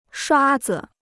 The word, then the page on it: 刷子: shuā zi